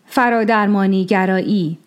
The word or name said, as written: Faradarmanism